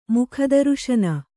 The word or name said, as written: ಮುಖದರುಶನ